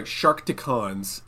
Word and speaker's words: Sharkticons: Actually, there's a, I want to mention this one, because there's a set, oh, sorry, Sharkticons is how it's pronounced.